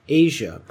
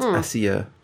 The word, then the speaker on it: Asia: Her name is pronounced Asia. – Asia. – How's it spelled? – A-S-I-A. But it's Italian. So it's Asia Argento.